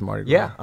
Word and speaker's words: Mardi Gras: You know they don't pronounce the S in Mardi Gras. – Grape Pie. – It's just Mardi Gras.